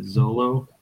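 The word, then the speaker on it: Xolo: I think you pronounce it Zolo.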